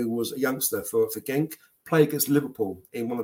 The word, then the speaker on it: Genk: I think it's called pronounced Genk, G-E-N-K.